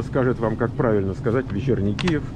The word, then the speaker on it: Вечерний Киев: Черный или черний? – Вечерний. – Вечерний. – Да. – Киев. Киев. Вечерний. Все, друзья, мы разобрались с произношением.